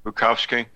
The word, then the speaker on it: Bukovsky: Jeff, I was just looking at your article published at the Epoch Times where you interview Soviet-era dissident Vladimir Bukovsky, did I say his name right? – Yes. – Bukovsky, attempting to warn us.